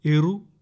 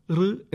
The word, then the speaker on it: ഋ: ഇറു, ഇറു, ഇറോ, ഇർ എന്നിങ്ങനെ തെറ്റായി ഈ അക്ഷരത്തെ ഉച്ചരിക്കാറുണ്ട്. എന്നാൽ ശരിയായ ഉച്ചാരണം ഋ എന്നതാണ്.